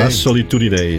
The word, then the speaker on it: La Solitudine: La Solitudine is het. – Ja, zo. – Hoor je het nou? – Zo ja. Moet je het uitspreken. Ja, dat is Italiaans.